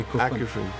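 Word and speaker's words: Akufen: Because there was this big post-rock scene in the mid-90s, then it came to Micro House, maybe with Ecufon, or how it's pronounced. – Sure, Ecufon.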